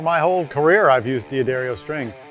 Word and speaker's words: D'Addario: And I say it right, D'Addario.